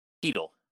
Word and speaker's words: Chytil: And actually, Chytil, is that how you say his name? – Cheadle? Philip Cheadle. Yep, Cheadle. Yep. – Cheadle. – Yeah, I made that mistake too. You see it and it's like, oh, Chytil.